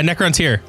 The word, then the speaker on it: Necrontyr: Necrontyr? Yeah, Necrontyr.